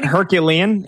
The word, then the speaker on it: Herculean: So like Fernando Mendoza would have to do like Hercules, Heroclean. How do you say that word? – Herculean. It would take a Herculean season.